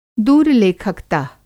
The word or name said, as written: दूरलेखकत: